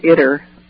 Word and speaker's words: ITER: and the program is called ITER, rhymes with bitter, it's spelled I-T-E-R.